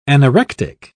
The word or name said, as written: anorectic